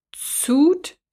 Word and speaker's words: Zoot: Suit, Suit Style. Also Z-O-O-T, Suit Style.